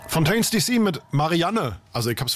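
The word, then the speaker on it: Fontaines D.C.: Und du hast es wirklich falsch ausgesprochen, weil es ist Fontaines DC.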